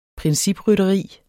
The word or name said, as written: principrytteri